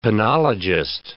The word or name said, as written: penologist